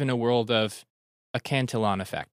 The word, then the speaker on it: Cantillon: I've actually mispronounced that for years. I used to call it Cantillion. – That's what I thought it was. – But I think it's actually spelled Cantillon.